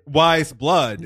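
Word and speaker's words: Weyes Blood: How would you spell it? – WISEBLOOD. – Totally wrong. – Is this the spelling bee again? – Of course. It never ends. – The indie musician spelling bee. – Yeah. – Yeah. How is it spelled? Is there a letter one in, or number one? – No numbers, but it's spelled WEYES, then blood, like blood.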